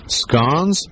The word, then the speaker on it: scones: Okay, is it actually pronounced scones or scones? – Scones. – And why is that? – Because if it was pronounced scones, there would not be any E in the word.